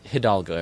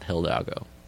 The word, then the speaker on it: Hidalgo: It's Hidalgo, everybody, not Hidalgo. That's a pet peeve of mine.